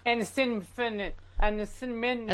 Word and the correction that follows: acetaminophen: What is the word? That's a hard one. How do you say it? Acetaminophen, sir. Acetaminophen.